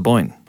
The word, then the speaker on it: Boyne: The Battle of the Boyne.